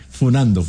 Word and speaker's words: funando: Exposición, exhibición, y ahora no se dice cascando, ahora se dice funeando. – Que usted, pero eso yo tuve un mensaje de 48 años, por favor. – Sí, funando, me aclaran.